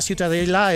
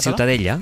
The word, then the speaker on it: Ciutadella: Para mí la ciutadella es... ¿cómo se pronuncia? – La catalán? Ciutadella.